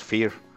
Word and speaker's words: Fier: O sea, Fear, el apellido de Sara es F-I-E-R, pero se pronuncian los dos Fear.